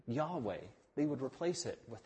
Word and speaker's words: Yahweh: In Hebrew, the name is probably pronounced Yahweh. No one's 100% sure. Yahweh is not like Bill or Bob.